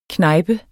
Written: knejpe

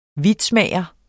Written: vitsmager

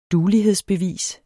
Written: duelighedsbevis

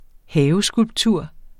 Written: haveskulptur

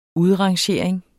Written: udrangering

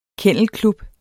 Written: kennelklub

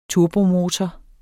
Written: turbomotor

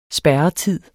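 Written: SPÆRRETID

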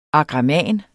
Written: AGRAMANERNES

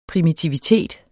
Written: primitivitet

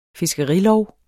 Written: fiskerilov

